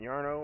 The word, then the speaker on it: Jarno: Jarno Trulli. – Yarno? – Yep, Yarno, or however you want to pronounce it.